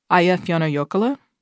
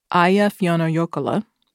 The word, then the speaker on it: Eyjafjallajökull: Eyjafjallajökull. Eyjafjallajökull. Eyjafjallajökull.